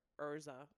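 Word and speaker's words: Erza: But yeah, no, I would love to cosplay as Ezra from or Urza. That's how they pronounce her name.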